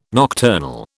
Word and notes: nocturnal: British English

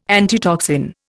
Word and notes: antitoxin: Indian

